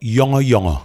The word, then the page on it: Yawngawyawngaw: HHLL